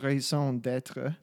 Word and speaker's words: raison d'être: is that how you actually say it like that? – Raison d'être. – D'être. – Yeah. Yeah. Raison d'être.